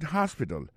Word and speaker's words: hospital: hospital li reme al visite l'hôpital l'hôpital se konsa di sa en anglais hospital alors accent tombe sur premier syllabe là gen trois syllabes puis she likes to visit hospital Ensuite, elle a écrit H-O-S-P-I-T-A-L, hospital, l'hôpital.